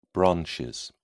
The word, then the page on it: branches: British English